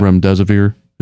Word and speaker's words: Remdesivir: We will give you 20% more than that if you would just use Remdesivir. – Yeah. – Remdesivir, is yes.